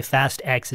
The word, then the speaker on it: Fast X: Yeah, we're talking about Fast and Furious because, of course, Fast 10, or as some people say, Fast X, is out in theaters this weekend. – I mean, it's spelled Fast X, so.